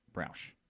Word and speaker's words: Browsh: I'm pretty sure it's how you say it, Browsh.